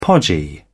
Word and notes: podgy: British English

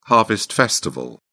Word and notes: harvest festival: British English

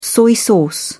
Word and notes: soy sauce: British English

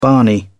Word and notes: barney: British English

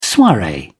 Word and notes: soiree: British English

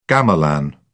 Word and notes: gamelan: British English

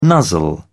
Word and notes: nuzzle: British English

